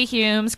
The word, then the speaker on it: Humes: It rhymes with my name, Kathy Humes, Crappy Fumes.